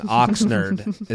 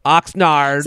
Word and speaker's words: Oxnard: So Oxnard, someone told me I was saying it wrong. Oxnard is how I was saying it. So Oxnard.